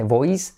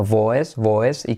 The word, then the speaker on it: voice: no se dice voice, sino voice, y como E, aunque es diptongo, pero suena a E,